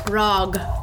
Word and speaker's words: grog: G-R-O-G. – Is it a glug? – Is it grug? I can't say it. Is grug? – Grug. – How do you spell it? – G-R-O-G. – Grog. – Grog. Just a grog.